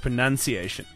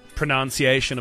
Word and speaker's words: pronunciation: But you know that technically it's not pronunciation, it's pronunciation. Did you know that? – Oh, yeah, yeah, yeah. – Oh. – Yeah, so yeah. You aren't supposed to say what is the pronunciation of a word.